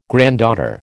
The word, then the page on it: granddaughter: American English